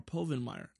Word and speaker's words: Povenmire: Join the creators and executive producers, Dan Provenmire. I think I'm saying that right. Provenmire. Or Povenmire. There's no R there.